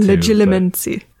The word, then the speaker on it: Legilimency: Legilimency.